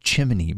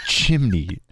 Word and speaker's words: chimney: Yeah, it's not pronounced chimney, Mori. It's chimney. It's C-H-I-M-N-E-Y. There's no extra I in there. It's not chimney.